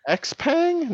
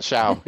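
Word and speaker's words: Xpeng: X-Pang, not Xiao. You're like, what? – Yeah. – X-Pang. – Believe me, before I moved to San Francisco, I would have said X-Pang.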